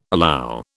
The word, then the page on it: allow: British English